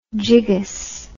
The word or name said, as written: jiggus